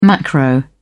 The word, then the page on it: macro: British English